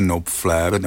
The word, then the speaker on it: Knopfler: A nenechte se mýlit, jeho jméno se sice skutečně správně vyslovuje Knopfler, nebo to je příjmení Knopfler, ale to K na začátku skutečně má.